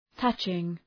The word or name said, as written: touching